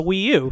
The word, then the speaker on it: Wii U: How do you pronounce the Wii U? Like that? The Wii U? – Yeah, the Wii U.